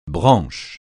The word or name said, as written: branche